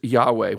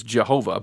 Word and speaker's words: Yahweh: In Hebrew, now this is Yahweh. When I was a boy, it was Jehovah, but apparently Hebrew scholars have changed their opinion of the pronunciation of this most holy name since then.